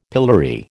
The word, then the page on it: pillory: American English